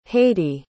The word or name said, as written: haydee